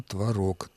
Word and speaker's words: творог: Творог и творог подаются в орфоэпических словарях как равноправные варианты ударения в этом слове, но эти орфоэпические словари составляются москвичами. В Петербурге предпочтительно, именно для старого петербургского произношения, а потому и для современного, предпочтительно форма творог. Так что ваша приятельница упрекала вас несправедливо.